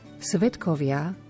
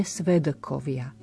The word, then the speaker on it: svedkovia: Svetkovia vyslovujeme s t v strede slova, nie svedkovia. Svetkovia spodobujeme.